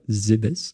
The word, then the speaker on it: Zebes: Notre protagoniste qui est euh, qui s'appelle Samus Aran, qui est euh, dans une armure euh, gigantesque orange, déambule sur la planète Zebes. Je ne sais pas si ça se prononce comme ça, Zebes. – Si, je crois que c'est ça, ouais.